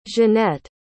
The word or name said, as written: jennette